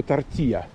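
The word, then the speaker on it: tortilla: Но на мексиканский вариант прононса это было бы тортия. Тортия. Два L произносится как.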